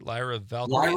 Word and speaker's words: Lyra Valkyria: You got, is it Lyra? Am I saying that right? Lyra Valkyria. Lyra Valkyria, that's right.